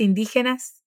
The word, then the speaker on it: indígenas: Pedro dice, ¿y las ropas indígenas? Esta unión GE en español también tiene la misma pronunciación de la R de inicio de palabras en portugués, indígenas.